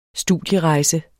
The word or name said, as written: studierejse